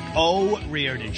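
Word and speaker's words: O'Riordan: Dolores, how do you say her name? – Oh, Reardon. She's Irish.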